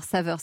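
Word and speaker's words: Savr: Alors Saveur, ça s'écrit S-A-V-R à l'anglaise.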